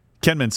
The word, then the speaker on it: Kinmen: Kinmen is how it's pronounced? – Kinmen, so Xiamen is where they were in China, and Kinmen is the island.